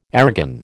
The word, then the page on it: airgun: American English